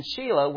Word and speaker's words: Shelah: It's the S-H-E-L-A-H. And so it's Shelah. Ur, you notice in verse 3, was named by Judah. Judah named him. But then the next two, Onan and Shelah, were named by his wife.